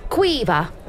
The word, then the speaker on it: Quiva: Quiva. – Quiva. – Pronounce the V. It's a hard V.